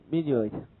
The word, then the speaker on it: minute: 在这里读成 minute 啊，微小的，对不对？